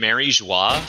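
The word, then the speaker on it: Mary Geoise: It actually is pronounced Mary Joie, but is that French? – It's funny because it's been Mary Joie for the better part of 15 years.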